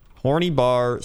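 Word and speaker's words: Horný Bar: It's pronounced horny with a rolling R and a long E sound for the Y. And bar, again with the rolling R. All the other letters are the same.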